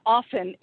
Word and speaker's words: often: And the T in often is silent, people.